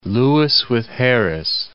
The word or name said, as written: Lewis with Harris